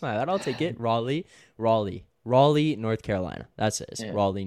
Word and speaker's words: Raleigh: I'm getting roasted for the pronunciation. Raleigh. What did I say? Raleigh? – He said rally? – Raleigh? Raleigh? Yeah, I might have said rally.